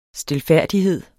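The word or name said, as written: stilfærdighedens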